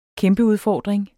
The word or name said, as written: kæmpeudfordring